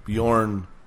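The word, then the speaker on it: Bjørn: Anyway, yeah, the J's are pronounced like Y's. – Yeah, Bjorn Solom.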